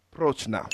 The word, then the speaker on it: Prochnow: Jürgen Perchow. Perchow. I can't pronounce it. – Almost there. Jürgen, right there. – Jürgen Prochnow. – Yeah, I buy that. – Fuck it. That sounds decent.